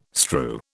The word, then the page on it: strew: British English